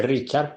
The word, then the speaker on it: Richard: El nombre, como es un alemán, se escribe Richard, pero se pronuncia Richard Avenarius.